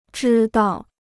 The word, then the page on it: 知道: zhī dào; zhī dao